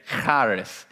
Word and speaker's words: charis: But if you want to say it right, you have to say charis.